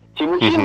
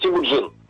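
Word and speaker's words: Темучин: так звучит на монгольском языке. Но назвали его в честь казненного татарского пленника, татар, татарского хана. По-татарски это будет Тимуджин. А, поэтому используют и то, и другое произношение. И монгольское, и татарское.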